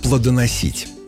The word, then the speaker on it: плодоносит: Одно из них – плодоносить. Да-да, именно так, с ударением на «и». При этом дерево у нас плодоносит с ударением на «о».